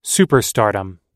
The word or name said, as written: superstardom